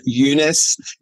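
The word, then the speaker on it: Younes: But on the other night, I heard that the correct pronunciation for our great Australian actor is Lincoln Yunus.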